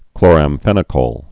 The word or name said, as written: chloramphenicol